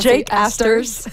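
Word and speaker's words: Jack Astor's: Donc c'est après la course au Jacques Astor. Astor, je l'ai prononcé vraiment français, là, mais je pense Jake Astors.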